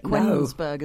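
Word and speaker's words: Kuenssberg: Laura Kussenberg. Is that how you say it? – Kunzberg, isn't it? – Kunzberg.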